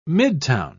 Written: midtown

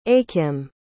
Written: achim

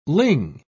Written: ling

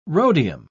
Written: rhodium

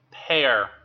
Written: pear

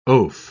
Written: oaf

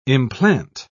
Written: implant

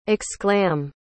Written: exclam

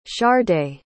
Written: chardae

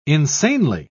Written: insanely

